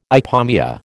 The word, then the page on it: ipomoea: American English